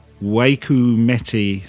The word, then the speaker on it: Waikumete: Waikumete Cemetery.